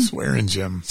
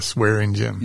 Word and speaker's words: Swaringim: Yeah, you cuss in gym. If you cuss in PE class, you're Swaringim.